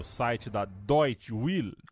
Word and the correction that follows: Deutsche Welle: Eu falei certo? Deutsche Welle? Eu é difícil, você sabe que é difícil. Deutsche né? Welle. Né? Deutsche Welle.